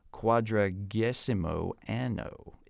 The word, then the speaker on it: Quadragesimo Anno: Quadragesimo Anno in 1931. I hope I'm pronouncing those right for the resident Catholic expert in our team. – Close enough.